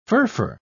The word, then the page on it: furfur: British English